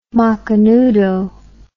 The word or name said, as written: macanudo